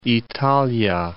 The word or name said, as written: Italia